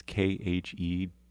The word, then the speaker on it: Khedive: It was actually commissioned by, I'm not entirely sure, I've only seen this in print, I don't know if I'm saying it correctly, the Khedive. It's Khedive.